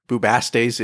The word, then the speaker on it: Bubastes: Well, we're going to talk about the cat of Bubastes, or Bubastes, if you're British. That's how they say it.